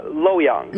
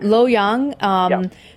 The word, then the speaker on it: Luoyang: Is that how you pronounce it? – Yeah, Luoyang. – Luoyang.